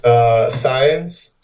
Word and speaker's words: Saenz: It's Saenz.